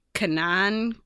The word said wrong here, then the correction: Kanan: Kanan, the last Padawan.